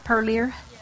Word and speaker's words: Parlier: Did I say that right? Parlier? Yeah. Alrighty. Parlier, North Carolina. That's like Pertnear, isn't it?